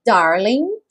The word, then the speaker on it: darling: So, darling, sem pronunciar o G, darling, stand by me, ou stand by me, ou stand by me, stand by me.